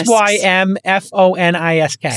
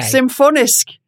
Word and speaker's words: Symfonisk: S y m f o n i s k. – Symfonisk.